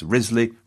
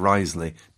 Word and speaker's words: Wriothesley: A similar calculation was made by Thomas Wriothesley, a leading exponent of the values of an unpronounceable surname, which has been variously pronounced Risley, Wriothesley and Risley, delete as applicable. Thomas Wriothesley was a cousin of Charles Wriothesley,